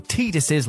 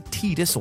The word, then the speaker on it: Tidus: There's perhaps no single video game scene more infuriatingly misunderstood than the infamous Tidus or Tidus, depending on how you want to pronounce it, laughing scene from Final Fantasy X.